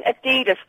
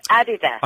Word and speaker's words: Adidas: Will you stop saying Adidas? Because it's not. It's Adidas.